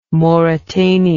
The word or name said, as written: mauretania